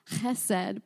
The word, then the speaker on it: chesed: And in Hebrew, it's pronounced chesed, but I'm not going to keep repeating it that way because I feel like that could be really distracting. Chesed.